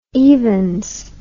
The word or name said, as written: evens